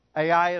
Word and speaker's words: Ai: And they pitched their tents between Bethel and, how would you pronounce the name of a city that is spelled Ai? Ai is correct, okay?